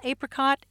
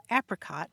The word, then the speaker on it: apricot: We had a conversation a while back about the pronunciation of that fruit that some people call apricot and some people call apricot.